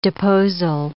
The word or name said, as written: deposal